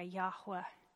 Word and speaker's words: Yahweh: Y-H-W-H. There's debate about those vowels. The vowels were not written down. But earliest manuscripts where there was some attempt at trying to show the pronunciation, that's why they've included the A and the E, like a Yahweh. So that's probably the closest to what was said.